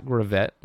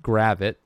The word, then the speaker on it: Gravett: Hassani Gravit, not Gravett, well, we now know that it's pronounced Gravit.